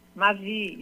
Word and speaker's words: Mavie: é Stacy, Maeve ou Mavi? – Mavi.